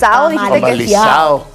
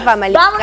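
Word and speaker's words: Famalicão: El famalizado. Sí. Porque no se dice famalicado.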